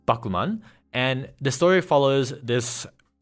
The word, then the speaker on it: Bakuman: The titles, in case people were thrown off by Katie's pronunciation, are Bakuman, Sono Biskudoru wa koi suru, and Eizouken ni wa te dasuna.